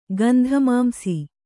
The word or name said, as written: ಗಂಧಮಾಂಸಿ